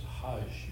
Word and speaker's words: Hajj: H-A-D-G-I, or Hadzi, if they use the Greek pronunciation. – In Arabic, it's Hajj.